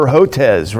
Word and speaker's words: Hotez: And they brought in outside experts, including the notorious Dr. Peter Hotez, right? Remember him? Is that how you pronounce it? – Yeah, but what about, did Fauci get invited? – No, he was there.